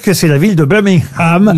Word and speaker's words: Birmingham: On ne doit pas prononcer le G, hein, il paraît. – Birmingham est effectivement...